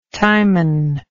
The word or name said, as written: timon